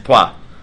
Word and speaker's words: Troyes: Troyes, Twa. That's, I believe, how it's pronounced.